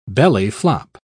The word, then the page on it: belly-flop: British English